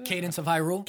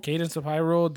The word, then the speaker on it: Cadence of Hyrule: So we got Candace of how do you say Cadence of Hyrule. – Cadence of Hyrule, Devil May Cry 5, Kingdom Hearts 3 again.